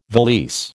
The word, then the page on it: valise: American English